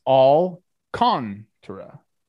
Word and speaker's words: Alcantara: And the pronunciation key on Baseball Reference is Alcantara. But I agree, people say it differently all the time.